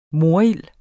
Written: morild

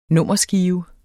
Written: nummerskive

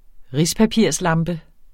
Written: RISPAPIRSLAMPE

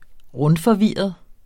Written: rundforvirret

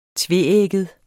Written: tveægget

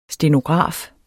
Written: stenograf

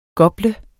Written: gople

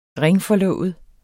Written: ringforlovet

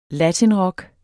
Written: latinrock

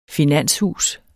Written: finanshus

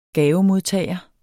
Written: gavemodtager

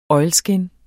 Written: oilskin

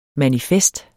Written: manifest

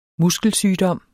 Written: muskelsygdom

